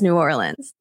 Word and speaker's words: New Orleans: I love how Sarah I'm- says New Orleans, by the way.